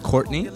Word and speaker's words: Quartni: Yo, so it's pronounced Courtney? – Yeah, Courtney Van Dorn. How's it spelled? – Q-U-A-R-T-N-I, and then Van Dorn.